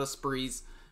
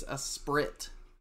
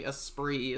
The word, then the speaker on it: Esprit: A lot of people call these Esprit. And it's actually Esprit is the correct way to pronounce the name. This was named from the Skywatcher European division, so it comes from a European background as far as the name goes, but it is officially pronounced Esprit.